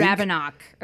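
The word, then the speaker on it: Ravinok: Ravnik. And is it the Ravnik or the Ravnok? I feel like I'm saying it wrong. – It's the Ravnok, I think. – Ravnok, okay.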